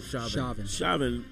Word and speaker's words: Chauvin: Chauvin actually had a history of abusing.